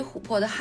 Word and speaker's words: 琥珀: 在中国的古代，琥珀被认为是老虎魂魄幻化成的宝石，所以琥珀的汉字中有“ 虎” 字。琥珀的“ 珀” 字和魂魄的“ 魄” 字是相同的读音。